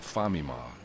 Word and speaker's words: Famima: Not too late, the other evening, I was stumbling out of a famima, supposedly it's the Japanese way of pronouncing Family Mart,